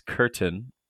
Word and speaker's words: Curtin: So my family name is Curtin, C-U-R-T-I-N.